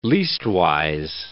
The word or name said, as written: leastwise